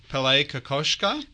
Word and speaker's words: Kokoschka: And Abby, you can correct my pronunciation, Pele Kokoschka. – That's right.